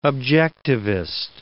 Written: objectivist

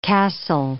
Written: castle

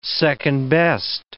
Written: second-best

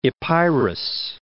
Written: Epirus

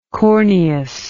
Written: corneous

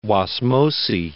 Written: Wasmosy

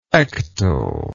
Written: ectally

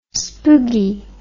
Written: spuggy

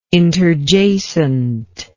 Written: interjacent

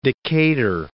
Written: Decatur

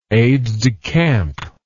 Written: aide de camp

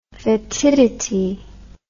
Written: fetidity

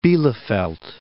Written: Bielefeld

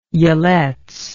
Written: elets

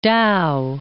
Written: Dou

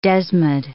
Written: desmid

